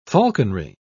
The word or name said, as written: falconry